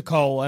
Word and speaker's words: Cole: Cole Young, and he'll be like, I gotta protect you. – Yeah, this will help you. It rhymes with Paul.